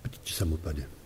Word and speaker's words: Paticca-samuppada: But in the Theravada teachings, we find one teaching which is called Paticca-samuppada. And when you hear the Dalai Lama give a speech, he very often uses that word, but he pronounces it very much with the mouth hardly open. Paticca-samuppada.